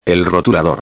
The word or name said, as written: el rotulador